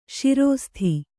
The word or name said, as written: ಸರಸತಿ